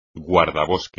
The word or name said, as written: guardabosque